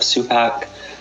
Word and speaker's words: Supak: And my name is Jeff Supak.